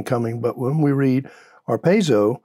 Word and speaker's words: harpazo: But as we read through the New Testament, we also see a word that's called arpazo. And in the Greek, it's spelled H-A-R-P-A-Z-O. When we translate to the English, arpazo.